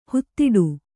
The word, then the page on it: ಹಿದೌಠ: hedauṭha